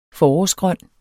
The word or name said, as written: forårsgrøn